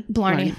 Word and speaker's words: Blarney: Blarney.